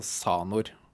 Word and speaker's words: Xanor: Men denne X-en, eller den KS-en foran i navnet, hvordan skal vi uttale den? Mener du? – Jeg mener vi går for S, eller en Z-lyd, altså Sanor. Ja, ok. Eh, Sanax, Sanor, ja. eh, som i liksom Altså xylofon, da, for mm.